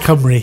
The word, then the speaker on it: Cymru: Cymru. How do you say it? – You say it Cymru. – Cymru.